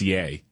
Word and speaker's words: Ye: Is it ye or yay? – It's yay.